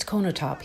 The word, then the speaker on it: Konotop: It's Konotop.